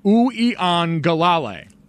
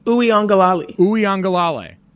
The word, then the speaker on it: Uiagalelei: Uiagalelei. – Uiagalelei. – Uiagalelei.